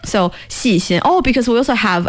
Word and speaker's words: 细心: How do you say that one again? – 细心. – 细心. – Perfect.